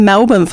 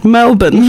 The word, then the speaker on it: Melbourne: Melbourne. – Melbourne. I love saying it like that. Melbourne without the R.